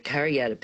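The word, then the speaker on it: Kariyarra: the Kariyatta people, that it's spelt with a double R, but it's pronounced like duh, and you Yep.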